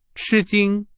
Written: 吃惊

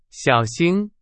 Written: 小型

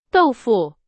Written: 豆腐

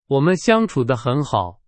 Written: 相处

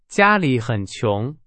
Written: 穷

